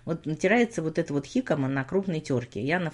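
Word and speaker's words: jícama: У нас называется хикама, пишется через J, как джикама, но произносится хикама.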